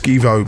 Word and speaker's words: SGVO: That's pronounced Skivo but spelled S G V O, and that was Reality.